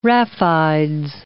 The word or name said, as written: raphides